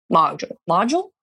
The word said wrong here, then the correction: mogul: How do you say that? – Mogul. – Thank you.